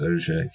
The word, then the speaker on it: Auger: This is a French word, so it's pronounced Auger.